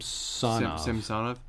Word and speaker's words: Samsonov: Samson, the goaltender for Washington, I can't pronounce his name. – Samsonov, that's what his name.